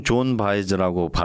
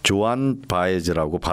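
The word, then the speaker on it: Joan Baez: 우리는 보통 존 바이즈라고 발음을 하는데요. 이곳 사람들은 조안 바이즈라고 발음을 하더라고요. 그래도 저는 존 바이즈라고 부르겠습니다.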